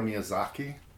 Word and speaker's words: Miyazaki: We have Princess Mononoke, from Hiro Miyazaki.